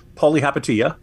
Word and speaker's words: Palihapitiya: And subsequent comments in that conversation don't make Palihapitiya, by the way, Greg pronounced it correctly the first time, good for him.